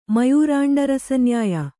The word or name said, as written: ಮಯೂರಾಂಡರಸನ್ಯಾಯ